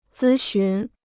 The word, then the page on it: 咨询: zī xún